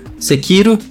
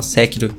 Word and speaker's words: Sekiro: Sekiro, vamos corrigir a pronúncia, tem gente que fala Sekiro. Sekiro. Uh, na verdade, tanto faz, é japonês, eu nem sei se é assim que pronuncia direito.